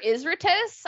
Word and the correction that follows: Izturis: I was thinking about him the other day. – Uh, Izturis? – Yes. Sorry, I said I said his last name wrong.